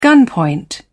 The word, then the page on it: gunpoint: British English